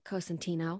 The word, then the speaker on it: Cosentino: So I'm talking with Erin Cosentino. Is that how you say it? – Cosentino.